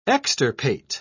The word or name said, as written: extirpate